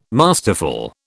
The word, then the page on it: masterful: British English